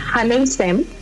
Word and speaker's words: HANNEULSSEM: La pronunciación correcta es Hanulsem, pero pues así nos van a encontrar en todo lado.